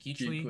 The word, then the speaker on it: Kuechly: They lost Newton, Luke Kuechly. Is that how you pronounce it? – Keechley. Keechley. – Yeah. Keechley.